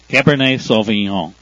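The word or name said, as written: Cabernet Sauvignon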